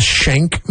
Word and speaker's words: Schenck: It's just, it's called the Shank Mansion. Can you do that? – It's, don't get shanked. – It sounds like the prison weapon, but it's spelt differently. It's like S-C-H-E-N-C-K mansion.